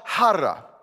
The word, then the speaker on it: chara: It's called chara. And you spell it C-H-A-R-A, but the C is silent. Chara. And this word is this deep sense of something happening.